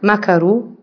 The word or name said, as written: macaroni